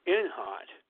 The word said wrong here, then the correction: Intralot: son was killed. – Intralot. – Yes, thank you, right?